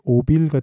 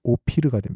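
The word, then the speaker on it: 오피르가 (אוֹפִיר): 그 사이에 들어있는 티끌이라는 단어는 어, 원어로 아파르인데 그 뒤에 오빌 같은 경우는 어, 원어식 발음으로 오피르가 됩니다.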